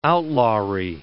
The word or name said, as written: outlawry